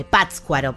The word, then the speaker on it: Pátzcuaro: En este acto los estados eh, americanos suscribieron el documento de Pátzcuaro, de Pátzcuaro para pronunciarlo mejor, en el cual se instituyó esta fecha.